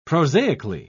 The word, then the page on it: prosaically: British English